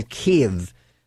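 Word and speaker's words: Kyiv: Russian troops are in Kiev, which is the right pronunciation and the way that they want it pronounced in Ukrainian. So in Kiev, the president of Ukraine says, "We are here in Kiev defending Ukraine."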